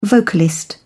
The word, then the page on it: vocalist: British English